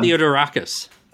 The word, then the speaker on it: Theodorakis: but Theodorakis. – Um, yeah. Mikis Theodorakis.